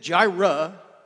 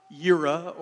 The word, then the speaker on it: Jireh: Jehovah Jireh, and Jireh just simply means, and then Jireh, okay, that's how you pronounce it, in case any of you are wondering. It's not Yira or anything else. Jireh, okay?